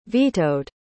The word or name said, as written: vetoed